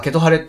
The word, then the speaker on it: ケトハレ: ケトハレって読むんですけれども、あちょっと難しい感じですね。